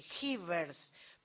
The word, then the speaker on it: Jivers: Esto es Jivers, que se escribe J-I-V corta, E-R-S, Jivers, pero son Jivers.